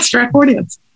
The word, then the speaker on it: Stratfordians: the Stratfordians, I guess you could call them, Stratfordians, I don't know. – Yeah, you said it right, Stratfordians.